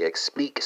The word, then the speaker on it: expliques: So Portuguese speakers, you know that that's explicas, spelled E-X-P-L-I-C-A-S. French speakers translate the Portuguese to the French. It's going to be explique, spelled E-X-P-L-I-Q-U-E-S. Remember that S is silent. Dutch speakers, you can chime in here. That's left out again, right? Spelled L-E-G space U-I-T.